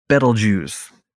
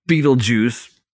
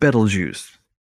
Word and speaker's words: Betelgeuse: I like to pronounce it Betelgeuse, not Beetlejuice. Dumb movie anyway. – Yeah, it does look kind of like that. – Betelgeuse.